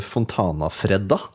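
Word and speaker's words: Fontanafredda: Fontana-Fredda. det, det, uttales ikke sånn. Det er italiensk boblevin. Ja, for det er ikke Freddan. Nei. det er ikke sørlandsk, liksom. Nei.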